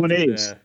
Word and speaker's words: Moniaive: Is it Moniaive where he lived? The convention that he started. – Moniaves. Yeah, yeah, yeah, that's right.